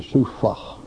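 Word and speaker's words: sufah (סופה): A, we have C-U-W-P-H-A-H, sufah. Spelled C-U-W-P-H-A-H, but it's pronounced like S-O-O-F-A-W, sufah.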